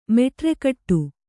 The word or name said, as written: ಮೆಟರುಗಟ್ಟು